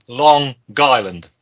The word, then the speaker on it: Long Island: Spoilers: SummerSlam 2002 from Long Island. No, sorry, do that again. From Long Island. There we go. Got to pronounce the G.